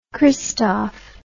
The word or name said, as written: Christoff